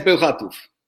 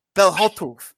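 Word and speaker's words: Bełchatów: I see GKS Belkatow. – No, GKS Belchatów. – Wow, I was off. GKS Belkatow. – Belchatów.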